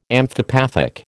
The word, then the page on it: amphipathic: American English